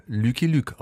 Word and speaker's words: Lucky Luke: Im französischen Original Lucky Luke ausgesprochen.